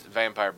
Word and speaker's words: Vampyr: It's Vampyr, but it's spelled.